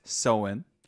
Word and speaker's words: Samhain: it is pronounced Soin. – Yes. – It is spelt Samhain.